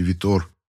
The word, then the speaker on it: Витор: Его вообще-то звали Виктор, но за ним закрепилось прозвище Витор с ударением на последний слог.